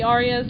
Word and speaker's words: ARIAs: Is that how people pronounce it, the Arias? Because that's how I've been saying it. It's an award... You think so? Okay, good. it's an award show in Australia.